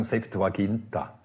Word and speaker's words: Septuaginta: Ja, gewöhnlich sagt man eben, wie es sagt, wir sagen Septuaginta.